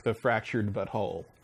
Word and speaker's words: The Fractured But Whole: Here, is South Park The Fractured But Whole. – I like you had the right emphasis on the right syllable.